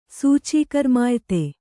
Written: ಸೂಚೀಕರ್ಮಾಯ್ತೆ

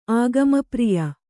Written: ಆಗಮಪ್ರಿಯ

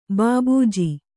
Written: ಭೂಭುಜ

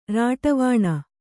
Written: ರಾಟವಾಣ

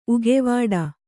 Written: ಉಗೆವಾಡ

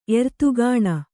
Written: ಎರ್ತುಗಾಣ

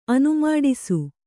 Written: ಅನುಮಾಡಿಸು

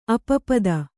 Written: ಅಪಪದ

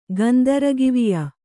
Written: ಗಂದರಗಿವಿಯ

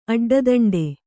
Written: ಅಡ್ಡದಂಡೆ

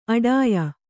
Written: ಆಢ್ಯೆ

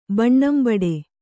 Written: ಬಣ್ಣಂಬಡೆ

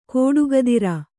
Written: ಖಡುಗಧಾರೆ

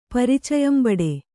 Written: ಪರಿಚಯಂಬಡೆ

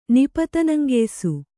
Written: ನಿಪತನಂಗೆಯ್ಸು

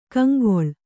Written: ಕಂಗೊಳ್

